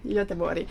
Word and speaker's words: Göteborg: La nostra città si chiama Göteborg in italiano, però in realtà la pronuncia è Göteborg. – Ah. In svedese.